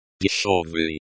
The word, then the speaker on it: дешёвый: This shirt is very дешёвый. – Дешёвый. Breakdown. – Дешёвый. – Дешёвый. Means cheap in Russian. Pronunciation. The year is pronounced like the yo in yo-yo. Stress. The stress is on the first syllable.